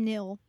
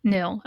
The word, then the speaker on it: Nihil: So is it Nihil? Neil? They're space Vikings. – Yeah, the Nil. – Nil, okay.